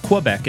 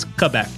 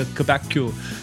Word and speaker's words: Quebec: It's not Quebec. It's Quebec. You have to say it like a Quebecu, like the Quebecians do. Quebec.